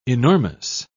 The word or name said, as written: enormous